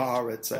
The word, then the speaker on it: Haaretz: I mean, this is Haaretz, which is I don't know if I'm pronouncing that right. – Haaretz, I think, yeah.